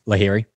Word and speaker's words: Lahiri: Lahiri.